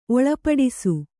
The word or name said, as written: ಒಳಪಡಿಸು